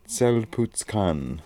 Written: Tzel putz kann

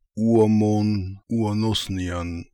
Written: Ooamonooanosnian

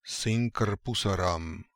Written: Sinkrpoosarams

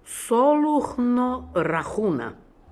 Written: Soloohnorahoona

